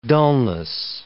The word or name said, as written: dulness